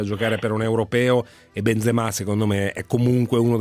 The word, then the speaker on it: Benzema: Piuttosto mi viene da pensare, datemi Benzema per la nazionale italiana, lo chiamiamo Benzema, senza l'accento sulla A,